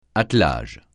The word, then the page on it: attelage: francais